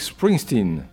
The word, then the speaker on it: Springsteen: Alors, le second film, redevenons sérieux un instant, avec Springsteen. C'est bien prononcé, non ? – Tout à fait, je ne l'aurais pas mieux fait, donc je vous laisse la prononciation.